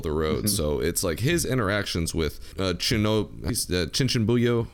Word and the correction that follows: Chunibyo: How do you say that? Yeah, the Chunin. It's Chunibyo.